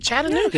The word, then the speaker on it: Chattanooga: The mom's letter to the Hamilton County Schools Board members from Chattanooga in the surrounding region. – You said that right. Chattanooga.